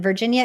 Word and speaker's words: Virginia: And Virginia is spelled V-I-R-G-I-N-I-A, like the state.